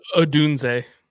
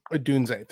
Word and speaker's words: Odunze: How is his name pronounced? Is it Rome Odunze or? – Odunze. – Odunze, thank you.